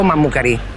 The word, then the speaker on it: Mammucari: Ho detto Teo Mammucari. – Sì, ma hai esasperato l'accento sulla I finale, come se volessi disprezzarlo.